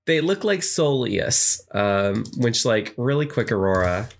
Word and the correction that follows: Solosis: I did mispronounce the name so badly now that I found it. – Oh, my gosh. It does look kind of like that. – Solosis. Yeah. Yeah.